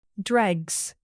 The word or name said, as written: dregs